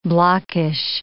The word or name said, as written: blockish